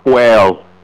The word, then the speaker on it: whale: I like that he pronounced the H in whale.